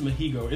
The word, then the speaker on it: Masego: It's Mahigo. It's like the G is like an H, and like the way it's pronounced, it's like an H.